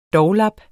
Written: doglap

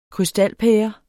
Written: krystalpære

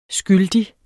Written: skyldig

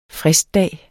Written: fristdag

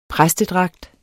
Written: præstedragt

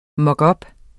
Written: mockup

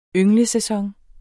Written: ynglesæson

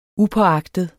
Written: upåagtet

